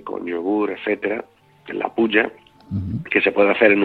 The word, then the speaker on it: puja: La puja, no sé si lo pronuncio bien, la puja, los yagyas y los samskaras, ¿no? ¿Lo he pronunciado bien, puja? – Sí, sí. Puja, que se refiere a la adoración en el templo, la adoración para la deidad.